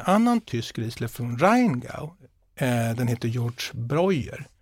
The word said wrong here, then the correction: Georg Breuer: Eller Georg Breuer heter den egentligen, det är lite, så ska det väl uttalas. Och det är eh, en eh, vinmakare som heter Teresa Breuer, som var hennes farfar som startade mm.